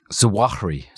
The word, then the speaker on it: Zawahiri: Zawahiri. – Zawahiri.